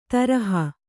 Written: ದ್ರೋಹಿ

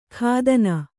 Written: ಖಾದನ

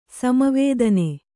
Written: ಸಮವೇದನೆ

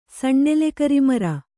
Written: ಸಣ್ಣೆಲೆಕರಿಮರ

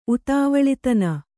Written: ಉತ್ತವಳತನ

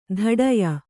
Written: ಧಡಿಯ

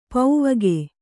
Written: ಪೌವಗೆ

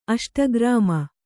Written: ಅಷ್ಟಕರ್ಮ

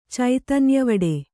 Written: ಚೈತನ್ಯವಡೆ

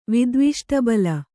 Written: ವಿದ್ವಿಷ್ಟಬಲ

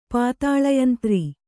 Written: ಪಾತಾಳಯಂತ್ರ

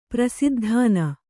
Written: ಪ್ರಸಿದ್ಧಾನ

